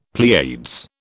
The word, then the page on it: Pleiades: British English